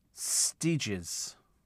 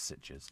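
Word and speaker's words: Sitges: Ben says it's pronounced Stiges. No, I've always called it Stitches.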